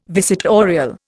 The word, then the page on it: visitorial: Indian